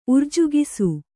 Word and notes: ಉರ್ಜುಗಿಸು: urjugisu

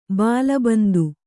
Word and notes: ಬಾಲಬಂದು: bāla bandu

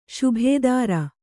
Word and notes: ಶುಭೇದಾರು: śubhēdāru

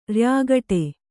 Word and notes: ರ‍್ಯಾಗಟೆ: kannada

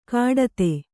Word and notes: ಘಟ್ಟಿತ: Kannada

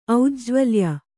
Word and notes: ಔಜ್ಜ್ವಲ್ಯ: aujjvalva

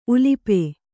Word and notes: ಉಲುಪಿ: Kannada

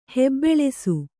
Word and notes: ಹೆಬ್ಬೆಳಸು: hebbeḷasu

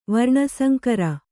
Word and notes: ವರ್ಣಸಂಕರ: varṇa sankara

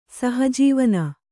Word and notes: ಸಹಜೀವನ: Kannada

